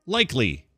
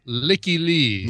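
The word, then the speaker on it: Lykke Li: Okay, I'm going to say likely. – That is incorrect. It is pronounced Licky Lee.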